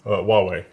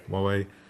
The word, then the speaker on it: Huawei: Huawei. – Huawei.